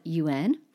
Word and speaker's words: une: So un is spelled un, and une is spelled U N E. And notice it's not un, it's un. It's got sort of a second little what I call a mini syllable after it.